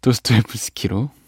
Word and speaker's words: 도스토옙스키: 네, 오늘 도스토옙스키를 제가 발음하기도 했었나봐요. 네, 다시 정정합니다. 도스토옙스키로.